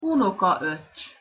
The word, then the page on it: unokaocs: Hungarian